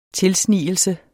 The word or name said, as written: TILSNIGELSERNE